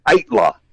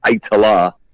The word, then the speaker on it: Aittala: You did a pretty good job, but the official pronunciation is Itla.